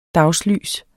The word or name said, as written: dagslys